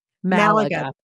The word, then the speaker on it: Malaga: Malaga.